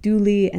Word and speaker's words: Dula: whose name is actually spelled Dula, D-U-L-A, but the local pronunciation is more like Dooley,